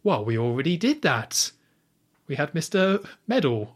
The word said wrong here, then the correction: Mendel: How dare you, Mendel. Mendel. Get his name right.